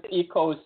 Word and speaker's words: Ekow: I was like, That was Eko Simpson. His first name is spelled E K O W Simpson.